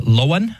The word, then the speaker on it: Loan: It was Loan, I believe is how you would pronounce it, L-O-A-N. It's spelled like loan, like you would get something loaned to you.